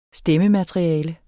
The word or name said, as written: stemmemateriale